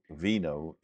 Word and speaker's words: vino: Don't forget the V in Spanish is pronounced B. So vino is actually vino.